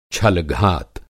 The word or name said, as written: छलघात